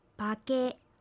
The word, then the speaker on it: パケ: バケは頭という意味です。アクセントはケにあります。